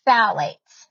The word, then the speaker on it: phthalates: Number 2, phthalates. This is a tricky one to spell because it does not sound the way it's spelled. It's P-H-T-H-A-L-A-T-E-S. Phthalates are chemicals in various beauty products, including fragrances, hairsprays, and nail polishes.